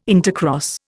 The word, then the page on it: intercross: Indian